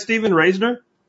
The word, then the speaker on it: Stephen Raisner: Did I say that right, Stephen Raisner? – Yes, you did.